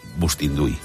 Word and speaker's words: Bustinduy: Que si el fiscal general, que si Marlaska con la Guardia Civil, pero hoy es Bustinduy, ¿no? Se dice así. – Pues parece que se dice así, sí, sí.